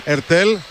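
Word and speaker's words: Heurtel: Τωμά Ερτέλ, χωρίς το σίγμα, το προφέρει ο Γάλλος.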